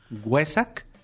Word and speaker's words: Wesak: Huesac. – Huesac, ¿cómo se escribe? – W-E-S-A-K.